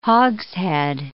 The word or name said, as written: hogshead